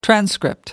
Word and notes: transcript: American English

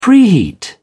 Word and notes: preheat: British English

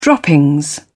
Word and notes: droppings: British English

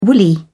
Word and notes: woolly: British English

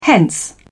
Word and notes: hence: British English

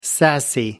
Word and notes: sassy: American English

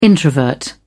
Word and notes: introvert: British English

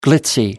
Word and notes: glitzy: American English

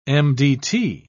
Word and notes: mdt: British English

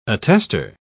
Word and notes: attestor: British English

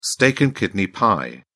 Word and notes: steak and kidney pie: British English

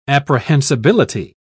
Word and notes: apprehensibility: British English

